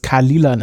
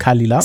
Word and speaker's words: Caol Ila: Jan hat mir mehrfach bestätigt, dass man es Kalila nennt. Also geschrieben C-A-O-L-I-L-A, also vielleicht auch K-O-L-I-L-A, aber ich bin der Meinung, es heißt Kalila.